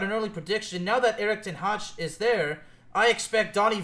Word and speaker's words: ten Hag: And since I'm skeptical, if they somehow win the Europa League with under the management of Erik ten Hag, which, by the way, that's how it's pronounced.